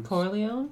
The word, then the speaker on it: Corleone: The Godfather, Carleone's Empire. – Corleone. – Yeah. Is that how you say it? – Yeah.